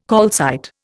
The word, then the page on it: calcite: Indian